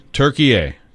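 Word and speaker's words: Türkiye: The big loss to Turkey A. I want to make sure I'm saying it correctly. Turkey A. Yes? – Yes.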